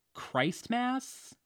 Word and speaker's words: Christmas: Like, if I saw that word for the first time in my life, I would say, Christmas? Christmas? Christmas? Like, how does he know the T is silent? Just a very nitpicky thing that does not matter at all, but...